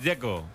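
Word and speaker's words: Džeko: El que me parece difícil es el de Checo, Dzeko, porque de Z, ¿cómo se pronuncia? – Dzeko. Bruno dice que es Dzeko. – Como tirando a J, Dzeko o algo así.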